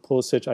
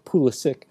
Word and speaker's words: Pulisic: You see the same with Pulisic. I keep using the German pronunciation, well, German and Slavic pronunciation because we're talking about Bundesliga, but Pulisic.